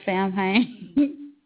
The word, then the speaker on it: Samhain: Yeah, I was thinking it was Samhain. – Yeah, well, yeah. But that's, I mean, that is the proper pronunciation.